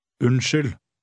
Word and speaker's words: Unnskyld: Look at number 9, which has a silent D at the end. Read 9. – Unnskyld.